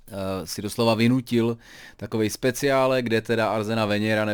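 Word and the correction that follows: Arsène Wenger: nevím proč jsem vyslovoval e, takhle, e, je to samozřejmě Arzen Wenger,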